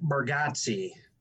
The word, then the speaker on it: Bargatze: I picked Nate Bargatze.